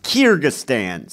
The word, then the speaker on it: Kyrgyzstan: I looked it up how it's pronounced. Kyrgyzstan.